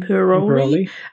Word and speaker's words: Imperioli: Uh, Michael in Imperial, oh, how do I say his last name? Michael Imperioli. – Imperioli uh, Christopher.